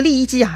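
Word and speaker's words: 郦食其: 另一个说的是刘邦手下的一个著名的能言善辩之人，叫利益姬。利益姬这三个字啊是比较特殊的，利呢是美丽的丽，右边加上双耳旁；益呢其实就是食物的食那个字，这个食啊是多音字，有三个音，益呢就是其中的一个了，用于人名。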